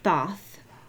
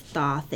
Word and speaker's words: Thoth: Yes, it was Thoth. – Yeah, I'm not sure how to say it, but thank you. – Yeah, I'm pretty sure it's Thoth. It's T-H-O-H.